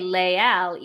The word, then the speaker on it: Les Halles: I asked my French teacher specifically about this because there is a Les Halles in France. And I think they say Les Halles, even though you would think because the H is silent, they should pronounce the S by French rules, but they don't.